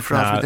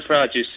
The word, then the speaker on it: Farage: You know Nigel Farage's surname? Farage. – F-A-Rage, yes. – Yeah. Oh, nice. – That's right. Do you know why it's not pronounced like garage? – No.